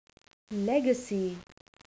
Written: legacy

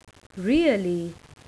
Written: really

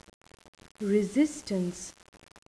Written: resistance

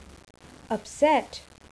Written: upset

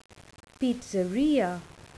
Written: pizzeria

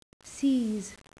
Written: seize

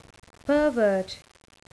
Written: pervert

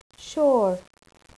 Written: sure

